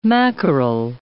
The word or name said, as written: mackerel